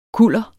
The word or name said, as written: kuller